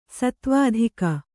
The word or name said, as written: ಸತ್ವಾಧಿಕ